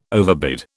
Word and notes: overbid: British English

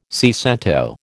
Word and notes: seicento: American English

